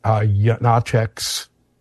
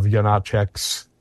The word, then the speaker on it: Janáček: Janáček's number one student, and the carrier of Janáček's, by the way, that's how it's pronounced. It's not Janáček. It's Janáček, where both the first two syllables are accented.